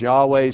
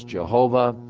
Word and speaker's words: Yahweh: Some pronounce it as Yahweh. Some say it's Jehovah. But Yahweh, translated in most Bibles, where they're all caps, L-O-R-D, all capital letters, is the name of God.